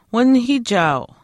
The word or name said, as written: winihiichaau